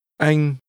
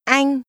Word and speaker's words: anh: Anh. – Is pronounced with a mid-level tone. Say it again. – Anh.